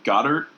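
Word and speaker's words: Goedert: Some guy named Goddard or whatever had 7 catches. – Yeah, but it's spelled weird. – Yeah. – It's like Geodude.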